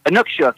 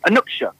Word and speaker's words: Inukshuk: Inukshuk, yeah. You Inukshuk. Did pretty good there. You slowed down a little bit. Inukshuk.